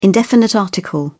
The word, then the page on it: indefinite article: British English